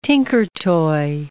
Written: Tinkertoy

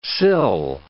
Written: sill

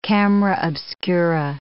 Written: camera obscura

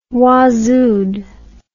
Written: waazooed

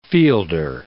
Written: fielder

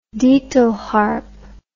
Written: ditalharp

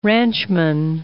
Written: ranchman

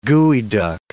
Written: geoduck

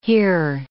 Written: here

